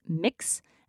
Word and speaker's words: Mx: In case you're wondering, a common gender-neutral honorific for those like formal occasions is MX, which is pronounced mix.